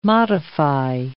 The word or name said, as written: modify